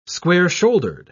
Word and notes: square-shouldered: British English

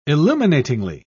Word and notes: illuminatingly: British English

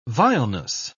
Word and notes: vileness: British English

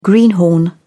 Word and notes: greenhorn: British English

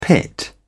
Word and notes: pit: British English

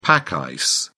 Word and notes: pack ice: British English

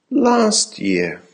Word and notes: last year: American English

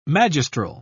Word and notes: magistral: British English

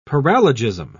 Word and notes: paralogism: British English

